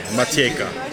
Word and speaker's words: Matiegka: M-A-T-I-E-G-K-A, Matieka.